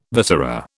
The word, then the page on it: viscera: British English